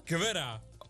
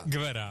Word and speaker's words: Juan Luis Guerra: Je spreekt hem uit als Juan Luis Guerra. – Guerra. – Guerra.